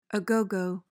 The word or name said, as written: a gogo